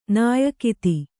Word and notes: ನಾಯಕಿತಿ: Kannada

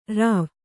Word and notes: ರುವಿ: ruvi

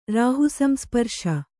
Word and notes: ರಾಹುಸಂಸ್ಪರ್ಶ: kannada

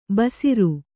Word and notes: ಭಾಸುರ: kannada

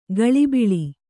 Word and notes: ಕುಳಿಬೀಳು: kuḷibīḷu

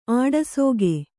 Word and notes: ಆಡಸೋಗೆ: āḍasōge

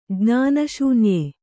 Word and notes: ಜ್ಞಾನಶೂನ್ಯೆ: jñāna śunye

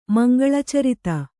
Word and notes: ಮಂಗಳಚರಿತ: mangaḷa carita

